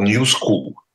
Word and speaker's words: New School: Я правильно сказал? New School. – Да? Правильно.